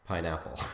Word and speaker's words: Pijnappel: Pineapple spelled P-I-J-N-A-P-P-E-L. So it's the Dutch pronunciation. – No kidding. – Pineapple.